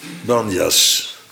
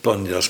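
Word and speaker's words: Banias: Waar zij, Jezus en zijn discipelen, terechtkomen in Banjas. Heet het tegenwoordig. Omdat de Arabieren de P niet kunnen uitspreken. Heet het Banjas. Het Panjas moeten zijn, maar vooruit maar weer.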